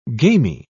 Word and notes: gamey: British English

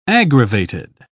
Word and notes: aggravated: British English

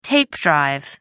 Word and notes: tape drive: American English

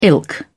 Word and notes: ilk: British English

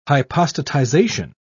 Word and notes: hypostatization: British English

